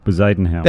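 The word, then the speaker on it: Bezuidenhout: Bezadenhout.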